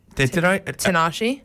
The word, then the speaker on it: Tinashe: Disco Lines with Tinashe. – Tinashe.